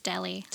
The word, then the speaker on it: Delhi: I think it's just Delhi. – Delhi? – Like the okay. Indian town.